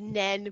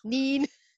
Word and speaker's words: Nene: the Nen could be anything. It could be Nina, Nene. – Nene, yes, I've heard it called the Nene before, yes. – Neen.